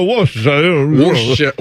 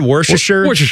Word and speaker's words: Worcestershire: So like, oh, it's a Worcestershire.